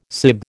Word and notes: sib: American English

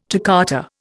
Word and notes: toccata: Indian

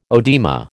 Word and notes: oedema: American English

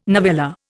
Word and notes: novella: Indian

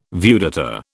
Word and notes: viewdata: British English

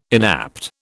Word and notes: inapt: British English

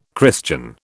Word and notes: Christian: British English

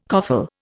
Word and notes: coffle: Indian

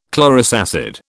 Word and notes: chlorous acid: British English

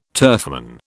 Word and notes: turfman: British English

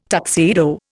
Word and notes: tuxedo: Indian

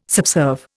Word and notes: subserve: Indian

